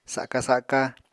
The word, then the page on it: saka-saka: Apatani